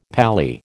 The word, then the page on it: pally: American English